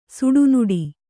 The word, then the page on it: ಸಿಡಿನುಡಿ: siḍi nuḍi